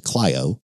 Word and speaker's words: Clio: He grew up in the small Alabama town of Clio, C-L-I-O, but pronounced Clio. – It is pronounced Clio? – It is.